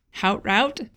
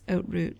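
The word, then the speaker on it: Haute Route: And if you're unfamiliar with this trail, it looks like Hout Route, if you're looking at it like an American. But I guess you don't pronounce the H, so it's Oat Route.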